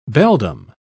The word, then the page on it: beldam: British English